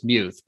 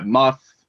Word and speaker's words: Muth: No, it should be like, they should put like a Y and an O in there mm-hmm. to like make it more like suitable to the way yep. it's pronounced. But yeah, Muth, I get that a lot.